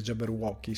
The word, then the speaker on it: Jabberwocky: Dei pinguini e tramite il Jabberwocky, che nel, non so perché il doppiaggio italiano è pronunciato male Jabberwocky, credo sia pronunciato male perché credo la pronuncia giusta sia Jabberwocky, se non ricordo male anche il film di Gill- Gilligan, ehm, Gillian, ehm, non Gilligan, Gillian.